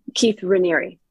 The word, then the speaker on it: Raniere: Keith Raniere.